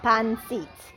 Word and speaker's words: pancit: Am I saying it wrong? Thank you for correcting me. How do we say it? Pancit. Pancit? Thanks for telling us.